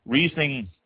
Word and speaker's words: Riesling: Riesling, R-I-E-S-L-I-N-G, pronounced Riesling.